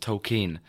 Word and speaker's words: Tolkien: It's pronounced Tolkien. – Tolkien. We've all been doing it wrong.